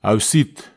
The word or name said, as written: ausit